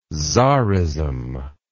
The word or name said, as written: czarism